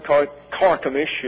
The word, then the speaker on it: Carchemish: In 717 B.C., Sargon conquered Carchemish, is the way it's correctly pronounced, and campaigned further north to Cilicia.